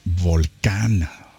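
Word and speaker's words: Volcán: And we listened to their brand new single, Volcan or Volcan, which is how you will pronounce it in beautiful Spanish.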